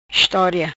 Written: Estoria

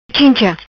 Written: Chincha

